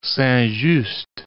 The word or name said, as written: Saint-Just